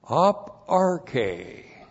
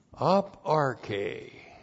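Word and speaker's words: Aparche (ἀπαρχή): Aparche. Aparche. Looks like that in the Greek. A-P-A-R-C-H. Long E, up RK.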